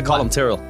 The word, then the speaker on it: Colum Tyrrell: Yeah, it was close. Yeah, Colum Tyrrell. – Colum Tyrrell. – Tyrrell. There's like a little sound that we don't make in English. Tyrrell.